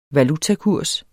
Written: valutakurs

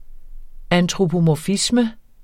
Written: Antropomorfisme

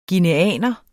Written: guineaner

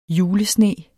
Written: julesne

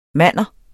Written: manner

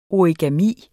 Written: origami

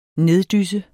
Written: neddysse

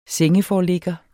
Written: sengeforligger

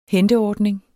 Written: henteordning

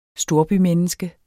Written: storbymenneske

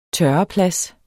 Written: tørreplads

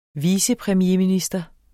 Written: vicepremierminister

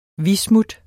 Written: bismut

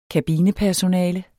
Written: kabinepersonale

